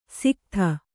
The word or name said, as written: ಸಿಕತ